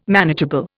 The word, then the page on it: manageable: Indian